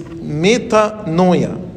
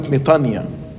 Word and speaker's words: metanoia: Repentance is, in Greek, is metanoia. Sometimes we pronounce it metania.